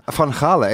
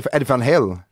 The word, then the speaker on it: Van Halen: Van Hale, ikke Eddie van Halen? Nei, nei, det, altså, skal du uttale Van Halen skikkelig? Altså, Eddie van Hale.